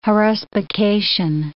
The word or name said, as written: haruspication